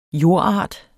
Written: JORDARTER

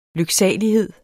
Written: lyksalighed

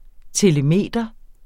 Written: telemeter

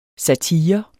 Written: satire

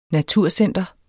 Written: naturcenter

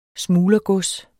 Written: smuglergods

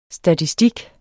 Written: statistik

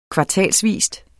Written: kvartalsvis

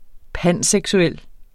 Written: panseksuel

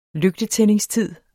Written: lygtetændingstid

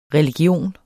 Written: RELIGIONER

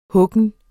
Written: huggen

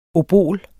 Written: obol

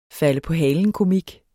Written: falde

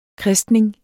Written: kristning

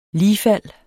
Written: ligfald

